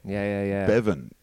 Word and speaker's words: Bevan: Bevan. Because it rhymes with Devon, doesn't it? – Yeah.